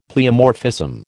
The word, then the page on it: pleomorphism: American English